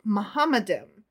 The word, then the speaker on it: Muhammadim: Zafar argues that in the original Hebrew, those last two words are pronounced Muhammadim with the suffix I-M, im, expressing respect and greatness, as in the Hebrew word Elohim, Elohim.